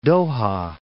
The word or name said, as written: Doha